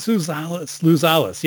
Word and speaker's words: Sluzalis: So Herman Parodi, Shailen Slalzavis. And Shailen, you can correct what I just can you say your last name for us? – Yes, no problem, David. It's Shailen Sluzalis. – Oh, Shailen Sluzalis.